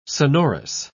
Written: sonorous

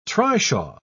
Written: trishaw

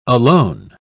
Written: alone

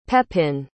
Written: pepin

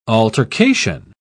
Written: altercation

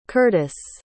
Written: curtis

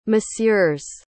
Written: monsieurs

